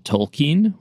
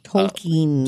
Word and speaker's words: Tolkien: It's called Tolkien, which is apparently the proper pronunciation of J.R.R. Tolkien's. – Tolkien. – Tolkien, because of his ancestry.